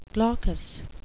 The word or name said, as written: glaucous